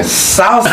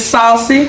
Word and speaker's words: Sauci: Saucy. My name is Saucy. – I was about to say your real name. – And it's spelled S-A-U-C-I.